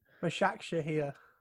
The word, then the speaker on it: Başakşehir: Başakşehir.